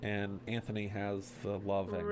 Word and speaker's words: Anthony: Well, the way they pronounced it back then, it was Antony. – Okay. – That's the way they pronounced it, but it's Anthony. It's spelled Anthony.